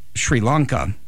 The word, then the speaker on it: Sri Lanka: my daughter told me that it's pronounced how? – Sri Lanka. – And I said Shri, and I should have said Shri.